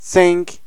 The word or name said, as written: cinq